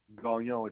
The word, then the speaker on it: Gagnon: Gagnon. It's spelled G-A-G-N-O-N. – O-M or N? – N like Natalie.